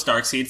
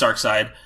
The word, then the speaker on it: Darkseid: And it's spelled S-E-I-D-E. – Yeah, yes. – S-E-I-D. I thought it was Darkseid for a long time, because that's how you would pronounce that. But it's Darkseid.